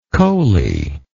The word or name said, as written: coaly